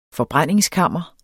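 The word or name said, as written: forbrændingskammer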